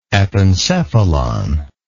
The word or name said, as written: epencephalon